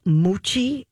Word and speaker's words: mochi: There are these little, I hope I'm saying it right, Moochie, M-O-C-H-I.